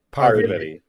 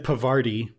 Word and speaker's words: Parvati: Parvati. Like poverty, but with like an poverty.